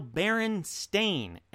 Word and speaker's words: Berenstain: It is no longer Berenstein, Berenstain Bears. It is now Berenstain, Stain.